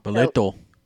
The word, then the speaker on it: Boleto: So, Backpack and, I said it wrong last time, Boleto? Bolito. – Boleto. – Boleto. Don't give me the accent.